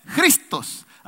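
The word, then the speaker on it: Cristos (Χριστός): Y se pronuncia así, mire, con J, se pronuncia, a ver, dígalo conmigo, se pronuncia Cristos.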